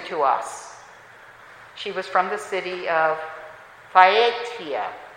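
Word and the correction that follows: Thyatira: No, it's pronounced, Phaiatira.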